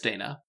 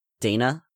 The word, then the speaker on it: Dana: And one of the things that Dana said was, is it Dana or Dana? – I believe it's Dana. – Dana? With a long A? – Yeah.